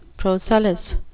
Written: procellous

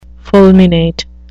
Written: fulminate

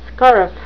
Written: scurf

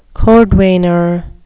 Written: cordwainer

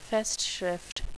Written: festschrift

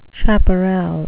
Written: chaparral

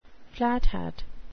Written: flathat

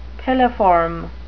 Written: piliform

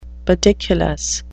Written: pediculous

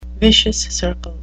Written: vicious circle

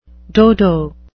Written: dodo

